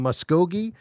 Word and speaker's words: Muskogee: Mike is in Muskogee, Oklahoma. Did I say that right, Mike? – Yeah, you said it right.